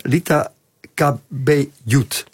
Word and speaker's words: Lita Cabellut: Lita Cabellut. Dan zeg ik het goed. Goed gezegd.